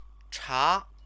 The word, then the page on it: cha: Chinese